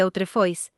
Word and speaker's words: autrefois: Aprovechando que, en francés, la antigua misa a veces se conoce como la Messe de Autrefois, en tiempos pasados, y que las expresiones Autrefois y Autrefoy, otra fe o una fe diferente, se pronuncian exactamente igual, transmitieron su opinión con un juego de palabras, la fin des Messis de Autre, FOI, une chance pour le Christ.